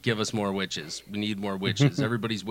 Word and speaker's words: Wytches: And after that one, I wrote down Witches, but it's spelled W-Y-T-C-H-E-S.